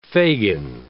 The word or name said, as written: fagin